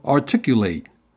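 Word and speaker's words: Articul8: It is spinning out a new platform company with the backing of Boca Raton, Florida-based asset manager and investor Digital Bridge, called Articulate, where it's spelled out as A-R-T-I-C-U-L, followed by the number 8.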